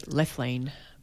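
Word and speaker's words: Lethlean: We just pronounce it Lithlean. – Lithlean. – But that's okay. Lithlean sounds interesting as well.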